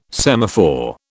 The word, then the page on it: semaphore: British English